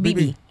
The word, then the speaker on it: Bebe: No, just B-E-B-E. – BB. – Oh, it's BB. – BB? Okay, BB Rex. Rex Ha. How you spell it? R-E-X-H-A.